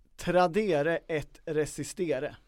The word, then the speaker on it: Tradere et resistere: Den heter Tradere et resistere. – Med reservation för uttalet. – Nej, latin uttalas precis på det sättet.